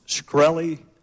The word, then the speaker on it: Shkreli: Is it pronounced Shkreli? – Yes, sir.